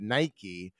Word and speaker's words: Nike: You thought Nike, which rhymes with spiky, not bike, and Adidas, more like Adidas than Adidas, were annoying enough, they weren't.